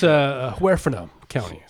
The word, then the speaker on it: Huerfano County: For the record, it's pronounced Huerfano County.